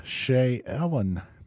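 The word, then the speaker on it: Shaelyn: Yeah, that's the Shea Ellen Band. Now, that's how I'm pronouncing it. It's S-H-A-E-L-Y-N. So I'm breaking it down, Shea and Ellen.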